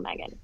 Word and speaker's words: Meagan: So my name is spelled M-E-A-G-A-N. My mom says it's the Southern way to spell Megan.